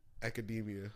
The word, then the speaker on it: Academia: Isn't it My Hero Academy? – Academia. – No, it's My Hero Academy. – All right. I don't even think it's spelled like Academy. – I think it's... – It's not? – It's My Hero Academia.